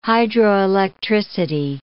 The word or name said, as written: hydroelectricity